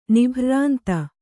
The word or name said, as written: ನಿಭ್ರ್ರಾಂತ